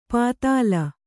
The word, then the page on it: ಪದಿಲ: padila